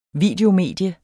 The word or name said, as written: videomedie